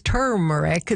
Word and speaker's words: turmeric: And in fact, there's an R in there, right? It's turmeric. It's kind of lost in the pronunciation.